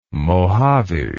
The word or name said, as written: Mohave